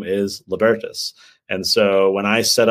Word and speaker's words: Libertas: I said Libertas and it's Libertas.